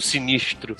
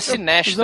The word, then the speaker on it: Sinestro: Tudo bem, é uma coisa, mas Sinestro e não Sinistro. – É porque o nome dele é Sinestro. – É o nome, Sinestro. É Sinestro. – Por acaso, você sempre achava. Eu sempre achava que eles estavam falando errado o nome. – Não, tô certo. – Aliás, tem... Mas não, é Sinistro, porra. É Sinestro.